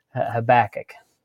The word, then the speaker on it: Habakkuk: And so I want to say like Habakkuk. – Yes, that's perfect.